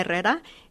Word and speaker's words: Herrera: Because when people see I-R-M-A, they say Irma. And if they see H-E-R-R-E-R-A, they say Herrera. And in Spanish, the H is silent.